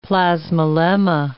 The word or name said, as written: plasmalemma